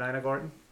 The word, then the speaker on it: Ina Garten: So, we're just going to talk about Ina Garten, a departure. Did I pronounce that right? Is it Ina Garten? – Right.